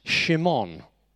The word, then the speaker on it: Shimon: Now, Peter or Simon, pronounced Shimon. Shimon, there are seven Shimons in the gospel.